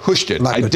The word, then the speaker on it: Houston: I think I'm now pronouncing it proper. Thank you. Houston. I didn't at first, but thank goodness we had people who were listening to the show when we started talking about it.